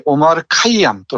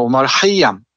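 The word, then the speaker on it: 오마르 하이엄 (عمر خیام): 오마르 카이암 또는 오마르 하이암, 오마르 하이암 그러는데 페르시아 발음에 충실하게 따르자면은 오마르 하이엄이라고 하네요.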